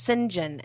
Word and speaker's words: St. John: And they do that, like, the name St. John in the UK is pronounced St. John.